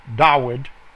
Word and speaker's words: Dawid: And the joy that King David felt, Dawid, proper pronunciation, in obeying and reading the laws of Yahweh.